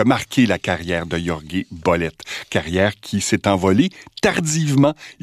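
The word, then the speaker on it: Jorge Bolet: un récital live du pianiste cubain Jorge Bollet, que je vais prononcer comme il le faisait lui-même, George Bollet, au Carnegie Hall de New York en février 1974.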